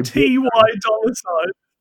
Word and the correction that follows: Ty Dolla Sign: Oh, God. – Yeah. – T.Y. Dolla Sign. It's Ty Dolla Sign. All right. – Well, it's spelt T.Y.